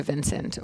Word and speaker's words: Vincent: You can find any of it if you just Google Sophia Vincent, or if you look up Sophia Vincent, which it's spelled normally V-I-N-C-E-N-T for the last name.